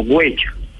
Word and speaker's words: guache: Guache al español traduce guerrero u hombre protector que cuida su territorio. No es el atarván ni el tosco, ni el grosero, que de hecho se debería pronunciar como huecho.